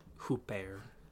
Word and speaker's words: Huppert: Isabelle Huppert for an international film called Huppert. That's how you say it.